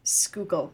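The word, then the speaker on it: Schuylkill: So I'm my coworkers are from Philadelphia, and I'm trying to remember how they say it. Skookle? – Skookle. Okay. I knew it wasn't going to be shoe because I was thinking of the Skylar sisters.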